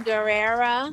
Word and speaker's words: Guerrera: Italian Guerrera. – My boy. Yes, you said it right this time.